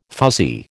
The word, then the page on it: fuzzy: American English